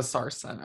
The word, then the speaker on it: sarsen: Of a sarsen. Okay. – Of a sarsen. – How do I spell sarsen? – Sarsen is spelled S-A-R-S-E-N. Sarsen.